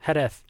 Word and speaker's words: Jerez: the first preseason testing is scheduled to happen at Jerez, Spain, February 1st through the 4th. – That's totally how you pronounce that, too. – Whatever. Jerez. How do you say it? – Jerez. – Jerez. – Kind of.